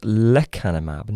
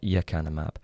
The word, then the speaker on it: lecanemab: And in fact, I've been pronouncing the drug wrong. It's lecanemab, not yecanemab.